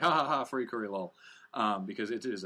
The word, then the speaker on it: FLCL: Oh, yes, FLCL, correctly pronounced Furikuri, is absolutely batshit insane.